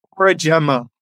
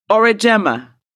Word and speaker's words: Aurigemma: Not even close. Aurigemma. Aurigemma. – Yeah. Aurigemma.